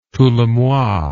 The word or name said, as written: tous les mois